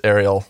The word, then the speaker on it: Ari: I don't correct people on my name because my full name is Ariel. So you just chop off the O and you get Ari, but lots of people pronounce it Ari.